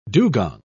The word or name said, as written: dugong